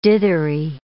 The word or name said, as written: dithery